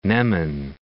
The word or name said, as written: Neman